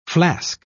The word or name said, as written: flask